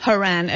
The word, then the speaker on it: Horan: Now, is it Horan or Horan? – Horan. – Okay, so the first hour I was saying it incorrectly with my English accent.